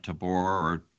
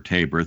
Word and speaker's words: Tabor: I would just point out that back in Hosanna Tabor, or Tabor, thank you, Erwin, for your pronunciation,